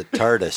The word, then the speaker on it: TARDIS: It's a TARDIS.